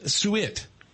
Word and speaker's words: suet: In the frozen food section, they had something called beef suet. I think that's how you say it, S-U-E-T. What is beef suet, and what do you do with it? – Suet.